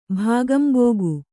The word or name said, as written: ಭಾಗಂಬೋಗು